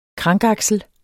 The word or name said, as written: krankaksel